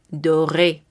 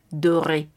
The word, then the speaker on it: doré: Pasemos al color dorado, que es D-O-R-E avec accent aigu, doré. Si tenemos un elemento que sería femenino, agregaremos otra E, pero la pronunciación será la misma a pesar de tener esta E al final y será doré. En el caso de agregarle el plural con S, lo mismo, será la misma pronunciación, doré. Pasemos al color verde, V-E-R-T.